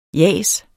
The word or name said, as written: jas